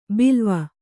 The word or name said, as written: ಬಲ್ಲವ